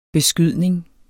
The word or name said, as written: beskydning